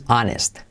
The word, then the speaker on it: honest: He's always honest. He's contraído. He's always, cuidado con la L, he's always honest. Y la H no se pronuncia, ¿vale? Ane, honest.